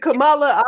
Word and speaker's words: Kamala: Hold on until Joe Biden and Kamala Harris is it Kamala? I just fucked up. – No, doll. Yeah, Kamala. – Kamala Harris. – Oh, yeah, you said it right.